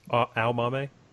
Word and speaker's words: Aomame: And then what I was saying about Aomame is, or I'm saying that wrong. How did you say it in the audiobook? – Aomame. – Okay. It's interesting because I've been saying it wrong the entire time in my head reading the book.